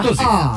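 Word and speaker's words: Å: E come si pronuncia? – Così. A.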